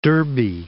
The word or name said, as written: Derbe